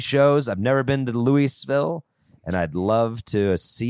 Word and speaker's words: Louisville: Is that how you say it? Louisville. – That's how you should say it. Louisville.